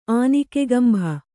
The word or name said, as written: ಆನಿಕೆಗಂಭ